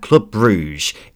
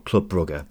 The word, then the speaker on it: Club Brugge: It's because he's the new manager at Club Bruges in Belgium, or Club Brugge, as it's apparently meant to be pronounced.